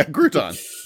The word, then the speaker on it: Cretton: Next in the MCU, the Shang-Chi director, Destin Daniel Cretton, I think is how you say his name. Might be Cretton. – Oh, that's unfortunate. – Yeah, it's Cretton. So it might be Mike Cretton. Cretton. – Cretton. There we go.